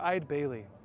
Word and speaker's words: Eide Bailly: We're Eide Bailly. Eide Bailly is pronounced like this, Eide, as in I'd like to work with you.